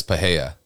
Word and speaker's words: Pereira: It's Alex, but it's Alex Pereira. No, it's two. It's double H's. Paheia. – Yeah. – Alex Paheia. – Yes. – So it's Alex Paheia. That's how you say it because he's Brazilian.